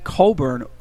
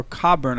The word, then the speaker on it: Cockburn: Apparently, and I'm not even still sure how to pronounce it, but in England, it's pronounced Coburn or Cockburn. I think it's Coburn. Like, they don't say Cockburn.